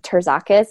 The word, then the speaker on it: Terzakis: My last name is pronounced Terzakis.